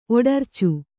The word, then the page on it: ಒಡರ್ಚು: oḍarcu